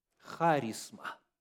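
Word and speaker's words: харизма (χάρισμα): Слово «дар» – это харизма. Харизма. Ударение на первый слог в греческом языке.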